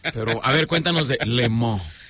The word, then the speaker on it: Le Mans: Bueno, voy a decirlo en castellano, Le Mans, pero ¿cómo es la pronunciación? – Le Mans. – Le Mans. Ok.